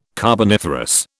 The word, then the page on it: carboniferous: British English